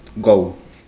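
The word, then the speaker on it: go (五): Y como te decía anteriormente, el 5 en el coche representa un homónimo japonés, ya que la palabra 5 se pronuncia muy parecido al inglés, go.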